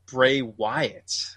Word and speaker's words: Bray Wyatt: Because there's some sort of rumor going around that Bray Wyatt.